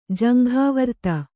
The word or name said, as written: ಜಂಘಾವರ್ತ